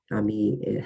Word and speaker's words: Ami: And this is one thing that Alex's book, Unsuspected Heroes, kind of goes into as well, is about how the main character, you're calling her Ami, huh? It looks like Amy to me, but yeah, it's spelled Ami, which is, I guess, friend. Ami, how her mom stumbles on her intelligence through facilitated communication.